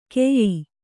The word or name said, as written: ಕೆಯ್ಯಿ